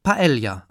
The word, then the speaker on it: Paella: Bei der Paella ahnen die meisten Deutschen offenbar, dass dort irgendwo ein J-Laut hingehört. Das beliebte spanische Resteessen wird jedenfalls zumeist richtig Paella ausgesprochen, sowohl mit L als auch mit J.